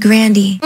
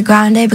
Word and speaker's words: Grande: My grandpa said Grandy. My brother kind of changed it to Grande because, like, Grandy was kind of like, I guess, Americanized version of it, you know?